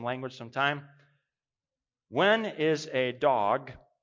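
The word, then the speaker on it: dog (דג): the Hebrew word for fish is pronounced dog. That's how you would say it, dog, but it means fish.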